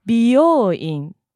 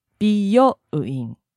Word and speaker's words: 美容院: I think you just pay attention to the accent and the syllables. 病 is one syllable for 病院, but 美容院, 美容, that's two syllables. 美容院.